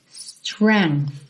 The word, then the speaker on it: strength: Atenção aí que essa palavra tem uma escrita um tanto estranha, né? S-T-R-E-N-G-T-H, mas você pronuncia strength. Então, começa no som do S. Strength. Strength. Uhum. E com arzinho agora, esse T-H, ok? No final.